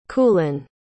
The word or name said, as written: culann